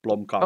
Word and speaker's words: Blomkamp: Good old South African boy, Neil Blomkamp. Is that how you pronounce his surname? – Yeah, Blomkamp.